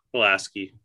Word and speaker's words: Pulaski: Pulaski.